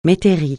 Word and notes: métairie: francais

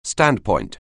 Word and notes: standpoint: anglais

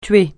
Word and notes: tuer: francais

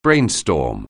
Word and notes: brainstorm: anglais

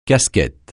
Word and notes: casquette: francais

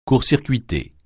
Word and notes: court-circuiter: French

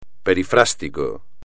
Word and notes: perifrástico: espagnol

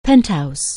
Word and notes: penthouse: anglais

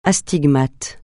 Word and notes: astigmate: francais

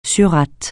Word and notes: surate: French

